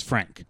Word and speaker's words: Phranque: Like, I didn't, like, Frank, which, by the way, is the name of the cube, Frank, apparently is spelled P-H-R-A-N-Q-U-E, but it's Frank.